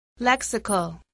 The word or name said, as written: lexical